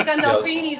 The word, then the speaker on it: Gandolfini: Galbafini, is it? – Galbafini, I think it is. – James Gandolfini. – Yeah. – I can't pronounce it, yeah. – James Gandolfini, that's it.